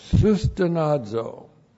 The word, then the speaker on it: sustenazo: S-U-S-T-E-N-A-Z, long O, sustenazo.